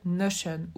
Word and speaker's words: Notion: c'est Notion ou Notion, mais c'est vrai que moi je le prononce plus à l'américaine, euh, donc je dis Notion, mais c'est euh, l'outil Notion. Si vous le dites un peu à la française, c'est Notion.